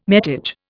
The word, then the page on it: metage: Indian